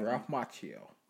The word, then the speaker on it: Macchio: What's how you pronounce his name? – Ralph Macchio. – Ralph Macchio, from the original movies.